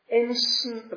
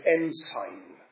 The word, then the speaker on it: ensign: The root of Jesse, which shall stand for an ensign of the people, I think I'm using the naval pronunciation there. Literally, it's ensign. But I think in naval circles it would be ensign.